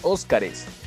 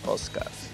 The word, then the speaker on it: Oscars: Transformers, Harry Potter, Piratas del Caribe por mencionar unos cuantos no se dice los Óscares, se dicen los Oscars. Bueno, en realidad le puedes llamar como quieras, pero la manera correcta es Oscars.